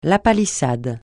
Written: lapalissade

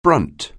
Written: brunt

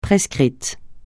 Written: prescrit

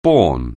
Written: bear

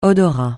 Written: odorat

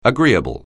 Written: agreeable

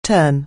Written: turn

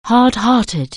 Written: hard-hearted

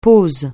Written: pose

